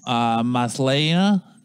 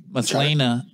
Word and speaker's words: Maslany: Tatiana Maslany. I just butchered that last name. Maslany. As the title role in She-Hulk.